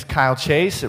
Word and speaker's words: Kyle Chase: If you don't know me, my name is Kyle Chase. It rhymes with smile face.